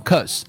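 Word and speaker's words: 'cause: 发成 a a a，所以 because 就读成了 because，甚至于那个 b 都不要了，就直接读成 cause cause。那么这个音在很多地方都会出现这个 a 这个元音，比如说 going to。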